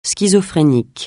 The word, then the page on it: schizophrénique: francais